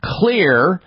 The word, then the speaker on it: Xlear: Clear Rescue Nasal Spray, X-L-E-A-R. That's how Clear is spelled. The X is stands for xylitol, one of the ingredients in the formulation.